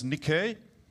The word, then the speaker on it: Nike: We pronounce it Nike or Nike. – Wir kennen alle das Wort. Wir nennen das Nike oder Nike. – And it's actually pronounced Nike.